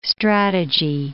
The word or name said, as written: strategy